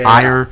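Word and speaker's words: Ajer: Yeah, but like I said, Christopher, the center back for Brentford, Christopher Ager. Ayer, how do you pronounce his name? Ayer.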